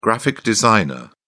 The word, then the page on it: graphic designer: British English